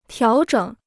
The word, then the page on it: 调整: tiáo zhěng